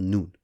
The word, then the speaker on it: Noon: And in Arabic, the letter N, when sounded out, is pronounced Noon.